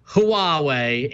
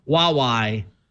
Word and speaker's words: Huawei: But if you zoom in at the bottom of the first answer, it says, although the Mandarin romanization is normally pronounced Huawei, H-W-A-W-A-Y, the Cantonese pronunciation is more like Huawei.